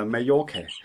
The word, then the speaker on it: Mallorca: så hedder det Mallorca. – Nej, det hedder Mallorca. På spansk to eller efter hinanden, det er udtalt som J.